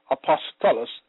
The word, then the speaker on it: apostolos: In the Greek, it is apostolos, pronounced apostolos, meaning delegate, an ambassador of the gospel, officially a commissioner of the gospel, messenger, one that is sent.